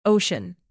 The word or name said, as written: ocean